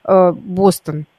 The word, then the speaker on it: Бостон: В слове «Бостон» правильно ставили ударение всегда на первый слог, пусть так и продолжает ставить «Бостон».